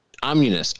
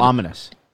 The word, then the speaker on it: ominous: I wrote Omnius. Oh, Ominous. He said. – Oh, Ominous. – He said Omnius at one point.